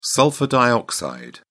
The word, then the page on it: sulphur dioxide: British English